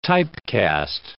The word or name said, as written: typecast